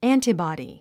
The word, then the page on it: antibody: ǽntəbɑ̀di; アンタボディ